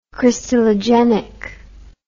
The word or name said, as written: crystallogenic